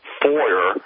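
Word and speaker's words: Foer: Next, The Atlantic's Nasha Bertrand and Franklin Foyer, pronunciation, pushed the Alpha Bank lies on MSNBC with Chris Hayes in 2018, and there's a video of it.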